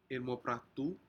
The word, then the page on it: inmopratu: Aneityum